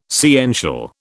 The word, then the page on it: sciential: British English